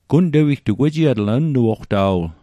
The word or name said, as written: wejiatl